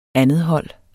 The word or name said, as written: andethold